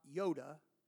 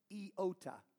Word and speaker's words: iota: If you ever heard somebody say something about not one iota, that's the English pronunciation of the Greek name for this tiny letter, iota. Not Yoda. We're not doing Star Wars or whatever, wherever that comes from, I don't know. But iota. I-O-T-A.